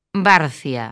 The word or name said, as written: Barcia